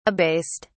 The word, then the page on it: abased: British English